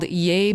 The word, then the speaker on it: Yeah: And that track was actually inspired by a song that was performed by Sam Cooke called Yay Man, or Yeah Man. I don't know. I pronounce it as Yay, and it's spelled Y-E-A-H.